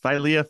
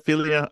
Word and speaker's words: philia: Yeah, philia, philia. I did look up some Greek pronunciation guides, and I work with some people that speak Greek, and I checked with them, and I think philia is acceptable.